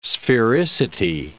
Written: sphericity

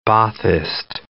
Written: Baathist

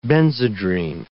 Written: Benzedrine

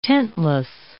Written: tentless

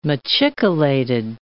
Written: machicolated

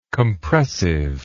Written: compressively

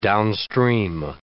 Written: downstream

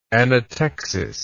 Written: anatexis

